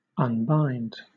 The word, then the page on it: unbind: British English